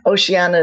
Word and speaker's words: Oceana: Oceana is spelled like ocean with an A at the end.